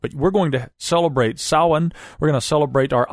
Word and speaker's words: Samhain: Well, Samhain is how it's written, but Samhain is apparently how it's pronounced.